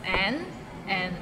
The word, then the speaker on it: 难: So it starts with N and ends with another N. Nan. And it's in a low tone, so nan.